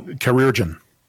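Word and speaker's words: chirurgeon: Is pronounced Kirgan? – No, Chirurgian. – Chirurgian. – Yeah.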